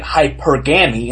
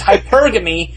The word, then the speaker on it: hypergamy: They were pronouncing it hypergamy, and I was like, no, it's hypergamy, the emphasis is on the middle syllable.